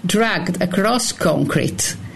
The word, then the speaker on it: Dragged Across Concrete: vediamo se lo pronuncio bene, Dragged Across Concrete. – Bravissima.